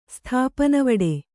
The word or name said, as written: ಸ್ಥಾಪನವಡೆ